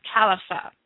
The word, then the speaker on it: Khilafah: It is more well known in English texts as the Caliphate, but the actual Arabic term is Caliphate, which is spelled K-H-I-L-A-F-A-H.